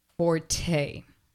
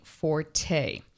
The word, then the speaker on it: forte: Now, the second one, which is a common word, and it's mispronounced all the time, is forte. We've been saying forte. How you actually say it is fort.